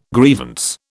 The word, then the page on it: grievance: British English